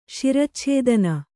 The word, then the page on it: ಶಿರಚ್ಛೇದನ: kannada